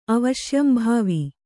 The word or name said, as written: ಅವಶ್ಯಂಭಾವಿ